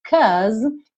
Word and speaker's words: 'cause: Olha só, esse 'cause, que é aí uma alternativa ao 'because', termina com uma letra E. Esse E não é pronunciado, tá pintadinho aqui de cinza. Logo, se você tá terminando nesse som aí de uma consoante, que é essa letra S, que tem esse somzinho de Z, 'cause, naturalmente você vai ligar esse som na próxima vogal, que é o I.